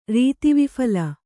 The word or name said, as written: ರೀತಿವಿಫಲ